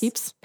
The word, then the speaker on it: Pepys: It's spelled, fun fact though, it's spelled P-E-P-Y-S. – Whack. P-E-P-Y-S. – Pepys. – Pepys, but it's pronounced Pepys.